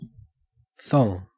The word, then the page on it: thong: Australian English